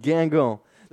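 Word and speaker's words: Guingamp: There's G-U-I-N. – Okay, G-U-I-N. – G-A-M-P. – G-A-M-P. – Yeah. It's like Gwyn Gamp, but it's pronounced Gangon.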